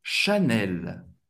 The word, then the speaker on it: Chanel: Chanel em francês é Chanel. Chanel. Chanel. Você vai pronunciar o L no final, mas um L bem francês. L. Chanel. CH tem o som do Ch.